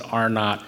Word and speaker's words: R-naught: It's the letter R with little zero in the corner. They pronounce it R-naught.